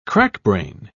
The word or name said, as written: crackbrain